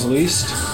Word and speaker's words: Liszt: Like Franz Liszt? – Some list.